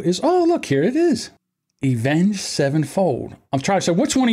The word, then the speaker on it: Avenged Sevenfold: What did I call it? Event Sevenfold? – Yeah, you called it Event Sevenfold. Maybe I did say it wrong. I know I have speaking issues sometimes. But yeah, no, it's Avenged Sevenfold.